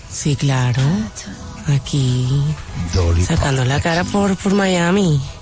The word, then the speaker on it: Dolly Parton: ¿cómo se dice Dolly Parton? – No, señor, Dolly Parton.